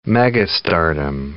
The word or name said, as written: megastardom